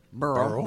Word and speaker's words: Beryl: B-E-R-Y-L. How would you say that? – Burl. – Burl, okay. – You said it right.